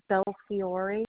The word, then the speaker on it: Belfiore: Belfiore, B-E-L-F-I-O-R-E, and it's 34608.